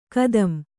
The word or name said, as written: ಖತಂ